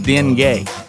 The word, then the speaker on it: dengue: The new mosquito-borne fever in the Florida Keys, it's pronounced dengue. Dengue fever.